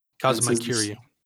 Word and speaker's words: Kazuma Kiryu: Yeah, how to pronounce it. – Kazuma Kiryu. – Yeah, see, you got it.